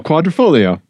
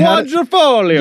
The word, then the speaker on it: Quadrifoglio: This is the Alfa Romeo Giulia Quadrifoglio. – Quadrifoglio. Yep, we learned Quadrifoglio.